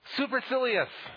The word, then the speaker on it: supercilious: So here's a fun one. Supercilious. And that is how you pronounce it.